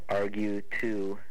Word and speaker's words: Argue: Argue2, all one word, Steve Argue2, A-R-G-U-E, is my last name, how it's spelled.